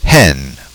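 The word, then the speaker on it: he (火): And that kanji of fire is pronounced as he.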